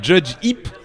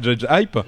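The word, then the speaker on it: JudgeHype: c'est-à-dire Julien de Judge Hip. On prononce Judge Hip et pas Judge Hype,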